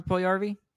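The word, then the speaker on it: Puljujärvi: Puljujarvi. That's Puljujarvi.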